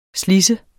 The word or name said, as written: slids